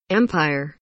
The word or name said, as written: empire